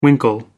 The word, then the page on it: winkle: American English